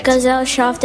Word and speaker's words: Gesellschaft: Gesellschaft? – Gesellschaft.